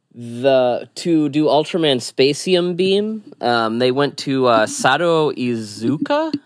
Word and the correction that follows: Sadao Iizuka: Yeah, Sadao Iizuka.